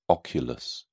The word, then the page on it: oculus: British English